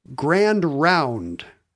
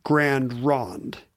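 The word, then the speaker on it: Grand Ronde: He has noticed in his own part of northern coastal Oregon that anyone older than 70 around Lincoln City, Oregon, says Grand Round for the name of that town, even though it's spelled Rond. Mm-hmm. And so anyone younger than 70 says Grand Rond.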